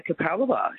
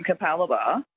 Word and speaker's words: Capalaba: Because if you look at it, it's C-A-P-A-L-B-A, Cap-L-Labba.